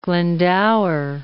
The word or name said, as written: Glendower